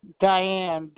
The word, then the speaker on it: Diane: Diane.